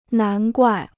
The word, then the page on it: 难怪: nán guài